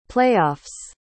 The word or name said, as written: playoffs